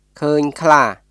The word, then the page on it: ឃើញខ្លា: クーニュ・クラー; kʰə̀ːɲ kʰlaː